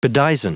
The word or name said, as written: bedizen